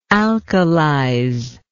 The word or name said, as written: alkalize